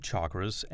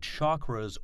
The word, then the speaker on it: chakra: So let's talk about the chakras and realize that they can be called chakras or chakras. Sometimes the C, C-H-A-K-R-A, is pronounced as an S, S-H-A-K-R-A. Either pronunciation is fine.